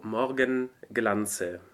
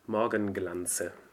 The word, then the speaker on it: Morgenglanze: So we just start with wie im Morgenglanze, because it's two words that are combined. It's mm-hmm. der Morgen und der Glanz. So Morgenglanze. – Exactly. – You have to pronounce both. Morgenglanze.